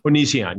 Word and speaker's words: Onision: Now, first, explain, I'm saying it right, Onision? – Onision, yeah, correct.